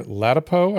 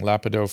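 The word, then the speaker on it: Ladapo: Tomorrow we have Dr. Latipo, I think is how we pronounce his name. I've been saying Lapido forever.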